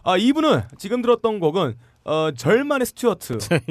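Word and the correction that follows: Jermaine: 뭐 절만 스튜어트라고 읽나요? 맞나요? 야, 저메인 네. 아니야 저메인? 저메인인가요? 아, 절만이 아니에요.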